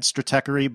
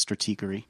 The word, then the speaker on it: Stratechery: I think it's Stratechery. – It's a long E. It's got the little thing on the E. – Every time. – I pronounce it in my head, Stratechery, but I believe it's Stratechery.